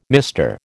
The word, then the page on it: mister: American English